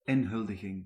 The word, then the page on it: inauguration: France; /i.nɔ.ɡy.ʁa.sjɔ̃/